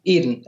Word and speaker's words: Eden: And Apples of Adun, I guess I pronounced that correctly. – Eden, Apples of Eden.